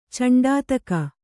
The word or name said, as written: ಚಂಡಾತಕ